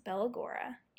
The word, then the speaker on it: Bella Gaura: So, Bellagora is pronounced Bella, which is pretty easy, right? And then Gora, like aura, like what color is your aura? But with a G, so Bella Gora, okay?